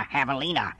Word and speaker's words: javelina: I am a javelina, spelled J-A-V-E-L-I-N-A. The J is silent.